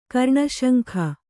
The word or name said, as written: ಕರ್ಣಶಂಖ